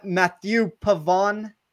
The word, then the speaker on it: Mathieu Pavon: I'm going to absolutely butcher his name, and David, you can correct me on this. Mathieu Pavon. – Nailed it. Yeah.